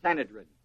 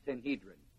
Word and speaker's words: Sanhedrin: And the ruling body is called the Sanhedrin, or as it's sometimes pronounced, Sanhedrin.